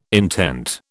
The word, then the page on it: intent: British English